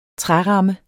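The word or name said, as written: træramme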